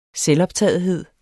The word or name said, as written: selvoptagethed